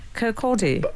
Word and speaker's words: Kirkcaldy: Kirkcaldy. – Okay, Kirkcaldy. – I should have left that. He's a Scotsman, but I'm pretty confident it's Kirkcaldy. – How do you say it? Kirkcaldy.